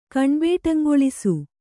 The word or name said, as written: ಕಣ್ಬೇಟಂಗೊಳಿಸು